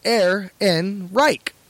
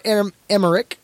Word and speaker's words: Ehrenreich: So, Riley, Aaron Reich is pronounced Ehrenreich. Easy as 1, 2, 3. So, see, I was closer. Not Emmerich. There are no M's in Ehrenreich.